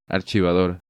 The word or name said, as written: Archivador